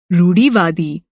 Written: रूढ़िवादी

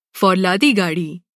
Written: फ़ौलादी

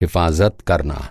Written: हिफ़ाज़त

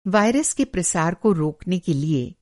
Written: प्रसार

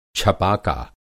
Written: छपाका

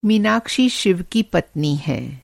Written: मीनाक्षी

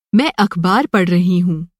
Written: अख़बार

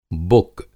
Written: बुक

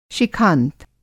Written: शिखांत

